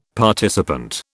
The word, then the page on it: participant: British English